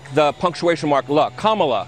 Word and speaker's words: Kamala: Kamala Harris.